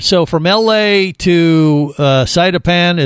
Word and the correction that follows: Sipadan: is that how you say it? How did you say that again? – Sipadan.